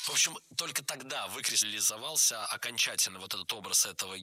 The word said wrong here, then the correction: выкристаллизовался: Было сказано «выкристаллизовался», но это неверно. Правильный вариант – «выкристаллизовался». Ударение падает на первый слог, на приставку «вы».